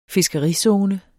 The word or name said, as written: fiskerizone